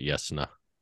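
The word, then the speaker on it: Jasnah: Well, in the books, they pronounce it Yasna.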